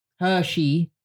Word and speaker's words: Hershey: Hershey. Hershey. – Oh, Hershey. Like in her and she. Hershey Kiss. – That would be how you'd pronounce it. Yeah, but it's not how it's spelled.